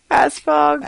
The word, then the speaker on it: Asphaug: A-S-P-H-A-U-G. How would you pronounce that? – Asphaug.